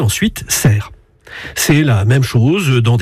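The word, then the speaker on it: Sercq: J'ajoute que la forme anglaise du nom de l'île s'écrit avec un A. Mais ce n'est pas pour cela qu'il faut dire Sarque. Ce serait ignorer la prononciation locale et la prononciation française.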